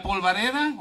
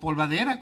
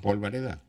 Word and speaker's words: polvareda: Oye, ¿y la polvareda o polvadera? ¿Cómo se dice? – Polvareda.